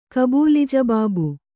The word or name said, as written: ಕಬೂಲಿಜಬಾಬು